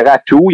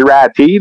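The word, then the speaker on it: Räty: ben, selon Elite Prospect, c'est Ratou. Il y en a qui disent Ratty, là, avec ça s'écrit R-A-T-Y. Moi, de ce que je comprends, c'est Ratou.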